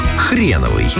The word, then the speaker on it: Хреновый: Один из самых известных островов – Хреновый. Его название часто вызывает улыбку, особенно если его читать, а не слушать. Но ударение здесь принципиально на первый слог.